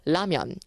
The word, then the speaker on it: 라면: 너무 라면을 굴려서 발음하지 마시고요. 라면. 이렇게 똑부러지게 발음을 하셔야 됩니다.